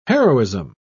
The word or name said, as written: heroism